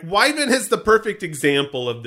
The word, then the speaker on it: Wyvern: No, I don't think the R is pronounced from what I've seen. But that's according to Oxford English Dictionary. Wyvern. – Okay, I'll take Wyvern.